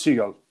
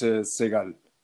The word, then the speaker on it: Segal: In English, Segal. – Segal. Okay. And are you originally? – In Spanish, it's Segal.